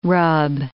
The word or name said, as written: rub